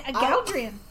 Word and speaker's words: Galdrien: You tell Aldrin. Galdrian. – I will tell him. – Oh, you mispronounced his name.